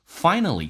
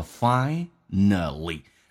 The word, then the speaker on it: finally: Finally, 这个单词呢英式有一个叫 finally。美式发音呢跟 l 在一起被所吸收。变成 finally.